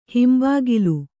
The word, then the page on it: ಹಿಂಬಗಲು: himbagalu